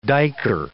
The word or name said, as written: duiker